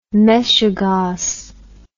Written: meshegoss

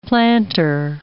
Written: plantar

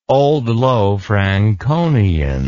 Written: Old Low Franconian

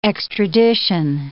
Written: extradition

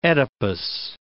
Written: Oedipus

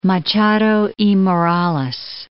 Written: Machado y Morales

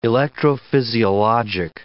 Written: electrophysiologic

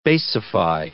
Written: basify

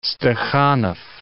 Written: Stakhanov

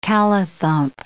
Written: callithump

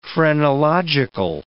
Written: phrenological

